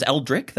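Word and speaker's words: Eldritch: So it's Eldric. That's the pronunciation. – Eldric.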